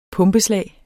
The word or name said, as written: pumpeslag